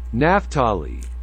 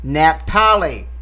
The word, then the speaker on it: Naphtali: From the tribe of Naphtali. – Naphtali, 12,000 were sealed.